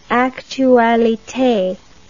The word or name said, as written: actualite